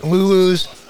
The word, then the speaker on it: Lulu's: You'll find me there or at Lulu's. Which on the commercials, they pronounce it Lulu, no S.